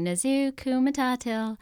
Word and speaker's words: Nezhukumatathil: I always tell them, you know, my last name is the same sound as the song from The Lion King, Hakuna Matata. So in order to pronounce it, I have them sing Nezhukumatathil, and they all get it, you know.